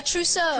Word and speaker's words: trousseau: A trousseau. – Trousseau is right. Yes, a trousseau. Nicely pronounced.